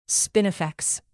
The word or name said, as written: spinifex